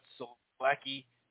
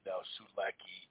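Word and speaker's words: Sulecki: So anyway, that's going to be on June 18th in Houston, Jermall Charlo and Selecki, I think is actually how you pronounce it with the Polish vibe in there.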